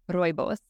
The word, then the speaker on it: Rooibos: So you have several different products, but you also, one of your ingredients is called Rubios. Did I say that correctly? – Rooibos.